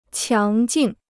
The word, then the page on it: 强劲: qiáng jìng